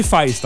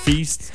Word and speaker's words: Feist: Ben c'est Feist en fait. – Feist? – Il y a des gens qui disent Feist. – Feist. – Fest, fest encore pire. – Fest. – Moi, je pensais que c'était laid en disant feast.